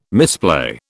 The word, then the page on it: misplay: British English